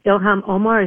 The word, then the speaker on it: Ilhan Omar: Well, I realized that after we had Keith Ellison and Ilhan Omar, is that how we say her name? – Yeah, that's it.